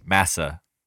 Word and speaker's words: massa: But one interesting thing is the pronunciation of master, which if you listen carefully is pronounced massa. Massa is the pronunciation of master in the dialect common to slaves when addressing a slave owner.